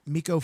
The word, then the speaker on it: Micco: Everyone knows Twitter at Miko Fey, MikoPhey. It's not how you spell my name, but it's phonetically how I'm spelled on Twitter.